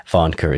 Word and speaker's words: Warncke: Varnker is spelled W-A-R-N-C-K-E.